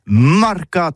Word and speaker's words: marcat: Eh bien, d'ici ça tout is, mais le mercat de l'Uni ou le mercat des rebelles, mais en gascogne toulousano, c'est prononcé les marcat avec un A en gascogne toulousaine, bah, du côté par exemple de l'île des Bâches, c'est l'île Jourdain, pour parler du marché, vous prononcez les marcat.